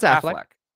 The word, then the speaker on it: Affleck: Am I saying it wrong? Wow. – No, it's Affleck. Yeah.